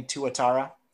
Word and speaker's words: Tuatara: Well, speaking of cars that ended up being wrecked, remember the Tuatara, the record-setting Tuatara? – I'm glad you said it correctly this time.